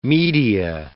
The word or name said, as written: media